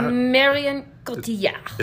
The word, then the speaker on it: Marion Cotillard: Is that Marion Cotillard. – Is that how you say her name? – Oui.